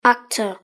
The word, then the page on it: actor: British English